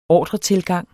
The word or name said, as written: ordretilgang